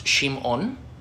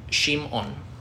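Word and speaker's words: Shim'on (שמעון): It's a Hebrew name. It's pronounced Shim'on, and it means like hearkening, like from the verb to listen. And so it's Shim'on. – Shim'on.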